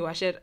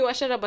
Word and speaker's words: Wacera: Everybody calls me Wacera. First of all, they call me Wacera, but they pronounce it even the wrong way.